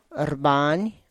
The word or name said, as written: rrbany